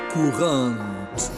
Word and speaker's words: courante: Courant. Courant se escribe courante y a veces se emplea la palabra italiana corrente o se dice, bueno, castellanizando, courant o courant, pero no tiene nada que ver con esto, jóvenes.